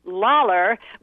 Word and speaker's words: Lawler: That is Phil Lawler, which rhymes with dollar, which you're going to get a lot of from this book because it's going to be marvelous.